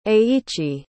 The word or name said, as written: eiichi